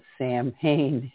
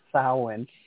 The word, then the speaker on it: Samhain: Halloween as the celebration day for what a lot of people mispronounce as Samhain. It's actually pronounced Samhain.